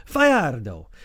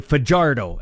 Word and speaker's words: Fajardo: And I asked him how you pronounce his name because he's of American Spanish descent. He said, Fajardo. Mm. But he said, not too many people know how to pronounce it. So he just let them butcher it and say Fajardo, as we would here in the Hick Prairies.